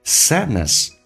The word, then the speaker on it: sadness: Sadness, 首先啊，那么那个 D 是不发音的，所以气流要阻塞，叫 sadness, 悲伤 and depression, 指的是沮丧或者表示抑郁的意思。Okay, the sadness and depression.